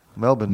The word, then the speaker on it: Melbourne: So I'm going to spell it in my mind, M-E-L-B-U-R-N. Melbourne. – Yeah, or even like take the R out at the end. Melbourne. – Take the what out? – The R out. Melbourne.